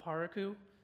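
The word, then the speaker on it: Parakou: It's called Paraku. That's the American pronunciation.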